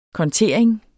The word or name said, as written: kontering